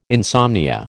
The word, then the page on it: insomnia: American English